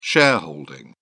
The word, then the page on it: shareholding: British English